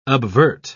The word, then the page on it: obvert: British English